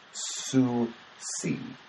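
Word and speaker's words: SUUSI: For you newbies, as you'd be called here, that's pronounced Sue-see, and stands for the week-long Southeast UU Summer Institute held in Radford University in the foothills of Virginia's Appalachians.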